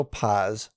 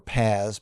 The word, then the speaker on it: Paz: This is a Connecticut state trooper whose name is Mitchell Paz, or Paz, P-A-Z is how it's spelled, let his girlfriend, Amanda Marino, use his laptop and then look at investigative records of her ex, who's Sean Rocha.